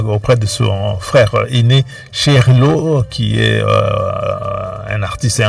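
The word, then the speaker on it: Cheikh Lô: Il y a plusieurs années, on a beaucoup joué des chansons de Cherilo. Sheikh Lo, pour le prononcer un peu plus lentement, elle-même Adjouza.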